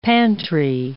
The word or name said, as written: pantry